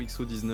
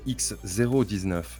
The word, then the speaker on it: X019: Est-ce que je l'ai bien prononcé C'est X019 ou XO19 euh, ah, Selon Graham Boydie, c'est X019.